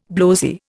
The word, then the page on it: blowzy: Indian